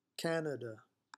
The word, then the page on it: Canada: English